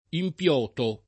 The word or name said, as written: impiotare